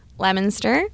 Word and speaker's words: Leominster: So we would say Leominster.